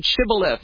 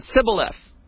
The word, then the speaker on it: shibboleth: They had to know, can you say the word shibboleth? Because some people didn't know, couldn't say it. They said sibboleth. They didn't say it right.